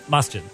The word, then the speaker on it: Masjid: How do I say that correctly? Masjid? – Yes. Masjid.